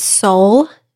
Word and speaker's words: Sole: Sometimes other people use it, but this treadmill, it's Sole, is how you pronounce it, Sole F80.